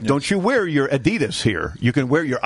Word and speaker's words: Adidas: Excuse me, Adidas. – Oh, sorry, Adidas. Well, this is, I'm using the English-Americanized pronunciation. – But the real name of the company is Adidas.